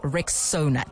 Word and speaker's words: Rexona: So it's pronounced Rexona.